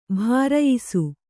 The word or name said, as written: ಭರಾಯಿಸು